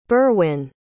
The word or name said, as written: Berwyn